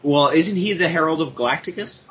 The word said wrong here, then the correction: Galactus: Galactus? Jesus Christ. – It's only three syllables.